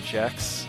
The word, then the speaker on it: Gex: But Gex Excuse is something me, it, someone. it's pronounced Jex.